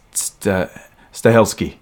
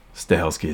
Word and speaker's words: Stahelski: They go, right, we have to say it's Stahelski. Stahelski is how you pronounce his name.